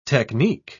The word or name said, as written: technique